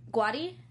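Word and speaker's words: Guaty: It's G-U-A-T-Y. – Guati? – Yeah, is that... Yeah, yeah. I think it's Guati, yeah.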